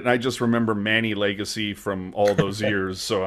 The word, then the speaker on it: Legace: Oh, and am I pronouncing Legacy correct? Because Hetty and Randy were pronouncing it not necessarily Legacy. – I read it as Legace, but I think that's wrong. – Okay, yeah, they might have been saying Legace.